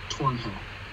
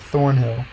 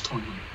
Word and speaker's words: Thornhill: Or is it Thornhill? – No, it's Tornhill. – Oh. – That's how I saw it pronounced. It's spelled Thornhill, but it's said like Tornhill.